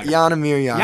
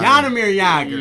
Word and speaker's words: Jaromír Jágr: His last name rhymes with Lager. – Wow. – Okay, I got it. Okay, Jaromír Jágr. – Jaromír Jágr.